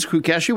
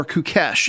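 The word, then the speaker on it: Kukës: Now, they play out of the city of, I'm going to pronounce it Kukes or Kukesh. It's K-U-K-E-S.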